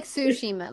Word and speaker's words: Tsushima: Am I saying that right? – Yeah. Mm-hmm. It's like Tsushima.